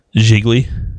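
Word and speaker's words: Gigli: And I think it's pronounced Jiggly.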